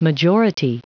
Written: majority

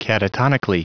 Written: catatonically

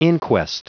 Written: inquest

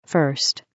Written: 1st

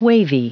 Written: wavy

